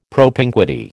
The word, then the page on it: propinquity: American English